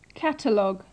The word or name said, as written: catalogue